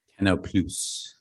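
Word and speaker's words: Canal+: Canal Plus.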